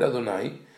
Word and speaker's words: Adonai: The word Adonai, even though we pronounce it as Adonai, which means my master or master of the universe, it is written famously as Yud and then Hey Vav Hey.